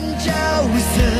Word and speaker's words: 角色: 歌词里“ 狠角色” 三个字的正确发音，明明应该是“ 狠角色” 嘛。 – 角色。